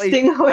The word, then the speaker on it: Tingjuy: It's Tingjuy. – I'm so sorry. – It's a Tingjuy, silent J, Tingjuy. So it's Tingjuy.